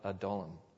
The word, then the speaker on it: Adullam: Now, the word Adullam is a word, as you might expect with a lot of biblical words, that some people pronounce differently. You'll hear some people pronounce it Adullam, or you'll hear some people pronounce it Adullam. But the best I could find in all the research I did was that the consensus is that it's the cave of Adullam.